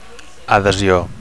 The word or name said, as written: adhesio